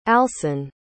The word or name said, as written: alson